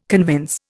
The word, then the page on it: convince: Indian